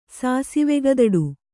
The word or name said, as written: ಸಾಸಿವೆಗದಡು